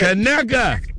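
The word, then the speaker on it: Conecuh: Yes, I rested and played with my new daughter, and the only thing I cooked, was some Kaneka sausage for my breakfast. – Kaneka. Is that, that's it, that is how you say it.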